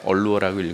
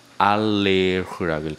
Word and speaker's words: allure: L-U-R-E 루어라는 것은 여러분이 루어 낚시인데 매혹, 미끼란 뜻이 있어요. 그리고 이거를 프랑스어로 읽으면 어떻게 되는지 알아요? 얼루어라고 읽으면 안 되고요. 알레흐라고 읽어야 돼요.